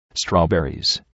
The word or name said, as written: strawberries